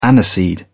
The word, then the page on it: aniseed: British English